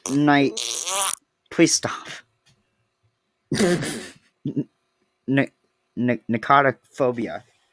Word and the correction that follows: nyctophobia: How do you say nicotophobia? – Nyctophobia. – Oh no, I got it wrong. – Got it wrong. – Nyctophobia.